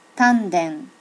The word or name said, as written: tanden